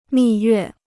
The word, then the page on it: 蜜月: mì yuè